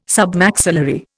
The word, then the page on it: Submaxillary: Indian